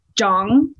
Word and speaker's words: Zhang: You actually say Zhang, like Zhang. – Like it's almost like a J, but like with a soft H after it.